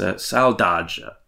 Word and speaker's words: saudade: And I'm going to try and get the pronunciation right. It's saudade. – Saudade. – Saudade. Right. It's spelled S-A-U-D-A-D-E.